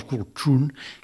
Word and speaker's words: Kultrun: Un famosíssim és el Kultrun, que s'ha de dir la T aquí pronunciada als alveols, Kultrun, que és com ho diuen allà, i aquest bombo és molt important ritualment.